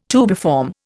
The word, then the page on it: tubiform: Indian